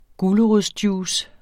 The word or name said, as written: gulerodsjuice